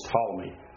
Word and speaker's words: Ptolemy: One of the four inheritors of the empire of the mighty king became stronger and greater than the others, and next was Ptolemy, and the P is silent.